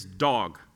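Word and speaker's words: dog (דג): The word for fish in Hebrew, how you pronounce it, is dog. And it means fish, okay? But it says dog.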